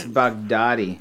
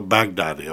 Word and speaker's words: Baghdadi: Abu Baghdadi or something like that. – It's Baghdadi. – Oh, Baghdadi.